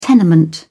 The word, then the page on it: tenement: British English